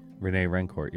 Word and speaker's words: Rene Rancourt: During the national anthem, Rene Rancourt, who always sang our national anthem. Did I say his name right? – Rene Rancourt, yeah.